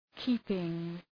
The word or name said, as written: keeping